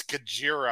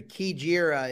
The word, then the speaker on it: Kajira: I think it's Kajira. I believe it sounds like a K sound.